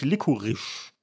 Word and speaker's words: Licorice: Das Wort für Lakritze falsch ausgesprochen. Es ist nämlich Licorice.